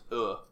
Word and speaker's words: y: The word for water in Guarani is just the letter Y, but it's pronounced uh, which I always thought was appropriate given it's like I'm super thirsty and I'm dying.